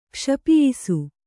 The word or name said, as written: ಕ್ಷಪಿಯಿಸು